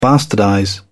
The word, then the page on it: bastardize: British English